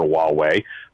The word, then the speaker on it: Huawei: This highway, or how do you pronounce it, H-U-E-W-E-I, highway? – Huawei.